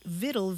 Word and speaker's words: vittle: And then Latin victualis passed into French, but along the way it lost that hard C sound, so it sounded more like vitaille. And that word for nourishment without the hard C found its way into English. And eventually people spelled it vittle, V-I-T-T-L-E, because that's sort of what it sounded like.